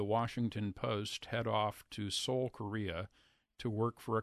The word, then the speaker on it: Seoul: S-E-O-U-L, as in Seoul, Korea.